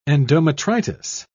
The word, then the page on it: endometritis: British English